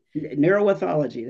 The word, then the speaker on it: neuroethology: And you have a master's in, I hope I said this right, neuroethology. – Neuroethology, that's correct.